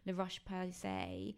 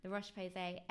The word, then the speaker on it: La Roche-Posay: I really relied on La Roche-Posay. I said that wrong. La Roche-Posay Effaclar Duo, which is, it's a little kind of tube of cream.